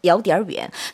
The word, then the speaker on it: 有点: 有点远. 사실 정확한 발음은 有点인데 빨리 발음하면 여로 들려요.